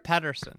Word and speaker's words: Pettersson: Pettersson. Am I saying that right? – Pettersson.